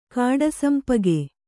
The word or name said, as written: ಕಾಡುಸಂಪಗೆ